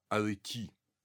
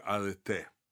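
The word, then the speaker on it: «арете» (ἀρετή): Это слово «арети» или «арете», ударение на последний слог – Поэтому вот слово, например, имя, известное церковное имя Филарет, оно означает «любитель доблести, любитель чести» – «филаретос».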